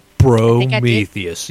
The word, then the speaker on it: Prometheus: All right, so there's a question here that I actually have found from Prometheus. Did I say that right? – Prometheus. Yes, you did.